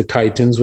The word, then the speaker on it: chitin: And the shell is called a chitin, or it's spelled C-H-I-T-I-N, a chitin.